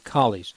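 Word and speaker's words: Colles: So his real the pronunciation should be Coley's.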